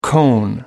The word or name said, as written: cone